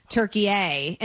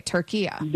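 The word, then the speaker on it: Türkiye: Okay, Turkiya. – There you go. That's perfect. That's how it's pronounced.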